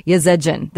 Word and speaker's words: Yazedjian: Yazedjian.